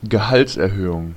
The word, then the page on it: raise: /reɪz/; American English; [reɪz]